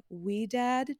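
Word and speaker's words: Ouidad: And I've used her gel, but I also like the Wee Dad gel. I think that's how you say it. It's O-U-I-D-A-D.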